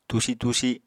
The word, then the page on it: tusi-tusi: Apatani